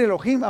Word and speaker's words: Elohim: Entonces, Elohim, eh, lo voy a decir Elohim, aunque la H no se pronuncia, pero como es de, como descendencia hebrea, es Elohim o Elohim, que significa Dios fuerte y poderoso, que en realidad eso es nuestro Dios.